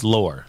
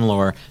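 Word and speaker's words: Loehr: I think it's Lair. Well, I listened to it just a second ago on Audible so we could say it right.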